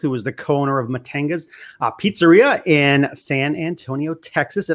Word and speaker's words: Matanga's: It is Matanga's. My husband's name is Matthew. So it's Matt, and part of my name is Anga, so that's why it's called Matanga's, Matanga's Pizzeria.